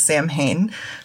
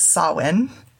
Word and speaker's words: Samhain: So a lot of it goes back to the druids, and then we also have Samhain, which a lot of people mispronounce, which I did for a long time, as Samhain, but since it's a Celtic word, it's Samhain, because everything in like, all Gaelic words, if you try to pronounce them phonetically the way they look, it's completely incorrect.